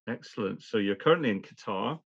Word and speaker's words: Qatar: So it depends on where you come from in the Gulf. So in Qatari Arabic, it's more Qatar with a G rather than a Q.